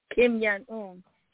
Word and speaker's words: Kim Jong-un: Jim Yang-yu, what do you say his name? Kim Jong-un.